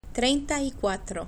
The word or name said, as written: trienta y cuatro